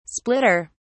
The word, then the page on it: splitter: British English